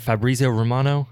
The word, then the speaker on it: Fabrizio Romano: Like I am at this point after the reports yesterday from how do you say his, is it Fabrizio Romano?